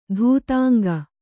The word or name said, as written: ಧೂತಾಂಗ